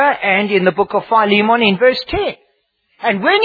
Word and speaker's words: Philemon: In South Africa, we pronounce it Philemon. But it's Philemon.